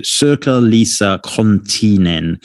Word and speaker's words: Sirkka-Liisa Konttinen: Sirkka-Liisa Konttinen. Sirkka-Liisa. Konttinen.